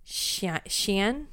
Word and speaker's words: Xian: It's spelled X-I-A-N, but it's pronounced like Xian.